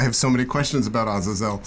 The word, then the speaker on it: Azazel: Azazel, that's how you say it? – That's how I always said Azazel. I think in Hebrew, probably Azazel.